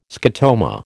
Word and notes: scotoma: American English

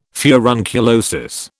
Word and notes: furunculosis: British English

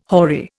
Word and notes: hoary: Indian

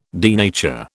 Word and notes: denature: British English

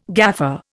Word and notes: gaffer: Indian

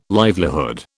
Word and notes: livelihood: British English

